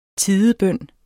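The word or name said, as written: TIDEBØN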